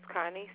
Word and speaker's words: Connie: The account is Connie, C-O-N-N-I-E, Turner, T-U-R-N-E-R.